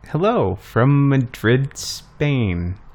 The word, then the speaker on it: Madrid: Because in Iowa, any town that's spelt Madrid, you say Madrid. – Well, that's the name of that town. That town's name is Madrid.